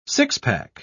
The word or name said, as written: six-pack